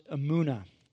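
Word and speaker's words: emunah (אמונה): And want to remind us from last week, a little Hebrew word that I left us with, a simple word called, pronounced emunah.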